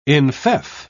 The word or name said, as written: enfeoff